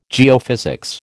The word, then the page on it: geophysics: American English